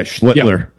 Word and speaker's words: Schlittler: I've seen probably Skeens pitch twice on TV, and I've seen, how you say his name, Schlittler? – Yeah, Schlittler.